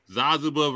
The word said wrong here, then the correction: Zabuza: versus Kakashi. – I'm sorry. Hold on. Say his name right. – Zabuza. – My man said Zazuza.